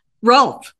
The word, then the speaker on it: Rohlf: Rolf. – Okay. Rolf. Yeah. I thought it was. Yes, the H is silent. So it's R-O-H-L-F, friends.